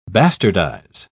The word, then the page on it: bastardise: British English